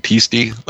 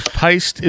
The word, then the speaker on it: peiste: And you said was P-I-S-T-E? – P-E-I-S-T-E. – Oh, P-E-I. Okay. – Yeah. So I was thinking peastie, like rhyming with beastie, but from what I could tell, it's actually pronounced peist, like something like that, peist.